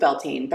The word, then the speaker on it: Beltane: Well, I pronounce it Beltane. There are other pronunciations. Mm-hmm. You have to remember different parts of the world, different ways we're saying things.